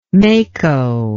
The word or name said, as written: mako